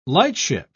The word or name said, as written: lightship